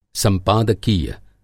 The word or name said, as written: संपादकीय